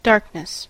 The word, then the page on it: darkness: American English